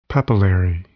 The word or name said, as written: papillary